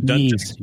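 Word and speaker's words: Ys: These are the confirmed games: R-Type, New Adventure Island, Ninja Spirit, Yes Book 1 and 2, or Yeez, or how you say it? – Yeez. – Yeez. Okay. Of course. – Yeah, like Kanye.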